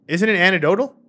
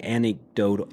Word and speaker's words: anecdotal: Oh, I just mispronounced it. Anecdotic. – But isn't it anecdotal? – No, it's like anecdotal.